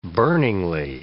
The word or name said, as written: burningly